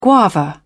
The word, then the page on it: guava: British English